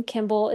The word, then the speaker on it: Kimball: And my last name, Kimball, is spelled K I M B A L L.